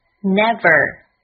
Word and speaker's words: never: V，它是一个咬唇音，上齿咬住下唇，发音的时候声带震动。要注意到中文里并没有这个音，所以要养成看到 V 要咬唇发音的习惯，不要发成 W。 ER，这里的发音是 R，注意末尾的卷舌音，连在一起 Never Never。